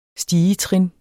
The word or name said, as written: stigetrin